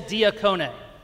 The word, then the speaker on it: diakone: Let me hear you all say diakone. Excellent pronunciation.